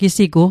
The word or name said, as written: gisigu